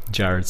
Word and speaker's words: Jarrad: It's just my my name, Jarrad Seng.